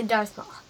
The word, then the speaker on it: Darth Maul: Darth Maul.